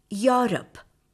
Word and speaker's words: Europe: Udaharanathina, M-E-E-T is meat, M-E-A-T is also meat. E-A-G-E-R, eager, E-A-S-Y, easy. C-H-I-E-F, chief. R-E-C-E-I-V-E, receive. When the letters E and U come together, they take the Y sound, as in Europe.